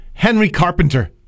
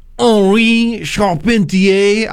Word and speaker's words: Henri Charpentier: When you just glance at the name, you might say Henry Carpenter, but actually, it's Henri Charpentier. I guess that's how you would say it if you were pronouncing it French in the French way.